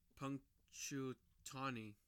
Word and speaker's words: Punxsutawney: All right, how do we say that? Can you say it? – Punxsutawney. – That's probably the closest one.